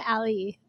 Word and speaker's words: sommelier: Sommelier? That's how you pronounce it? – Sommelier. I'm a Somm.